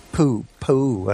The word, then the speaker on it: Pou: And they're up against a very similar figure to Marina Silva, a young candidate called Luis Lacalle Pou, I think is the way his surname's pronounced, P-O-U.